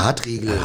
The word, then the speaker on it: Hartriegel: Hartegel hieß es doch nicht, Hartegel. – Quatsch, Riegel. – Regel. – Blutrote Hartriegel. – Hartriegel, sowas.